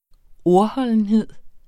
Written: ordholdenhed

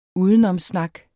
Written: UDENOMSSNAK

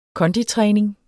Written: konditræning

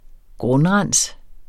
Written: grundrens

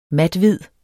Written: mathvid

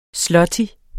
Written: zloty

